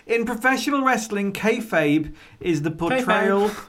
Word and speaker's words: Kayfabe: Am I pronouncing that correctly? – Kayfabe. – Kayfabe. – That's what it sounds like.